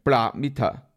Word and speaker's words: Paramita: 跟住就波罗蜜多就 Paramita 啦，啊，咁样我头先咧用咗另一个嘅梵文读音，应该 Paramita。